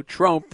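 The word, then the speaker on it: Tromp: And look, last night, and Chadwick Trump, you have to say his name properly with a long O, Trump, because you don't want to make a mistake here and call him something else, which we don't want to do.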